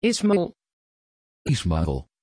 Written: ishmael

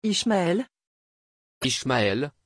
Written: ishmael

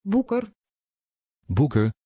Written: booker

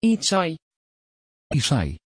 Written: isaï

